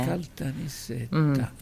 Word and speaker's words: Caltanissetta: A Caltanissetta. – L'hai detto bene? L'ha detto bene? – Caltanissetta. Sì, l'ho detto bene.